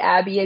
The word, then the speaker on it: Abbey: Abby Fickley. Abby is A-B-B-E-Y.